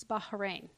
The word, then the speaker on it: Bahrain: She mispronounced Bahrain. – How'd she pronounce it? – Bahrain? It's Bahrain.